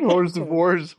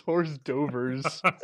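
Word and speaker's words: hors d'oeuvres: That's how you pronounce it, right? – Not even horse divorce, horse dovers.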